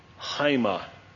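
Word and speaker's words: Haima (αἷμα): This one is not pronounced. It's simply, it's a placeholder, an apostrophe, a glottal stop, however you want to word it. So with that in mind, and I'm just going to draw this line here to divide the syllable, Haima.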